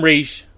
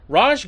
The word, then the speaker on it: Ra's: So Ra's shows the puffy pants we saw earlier, which, okay, Ra's, I call him Ra's. I know now it's pronounced Ra's, but in this cartoon he's called Ra's.